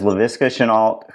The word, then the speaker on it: Laviska Shenault: That is Laviska Shenault. Did I say it right? – Yeah. Yes, okay. You did. – That is Laviska Shenault, who has 21 catches for 388 yards and two touchdowns.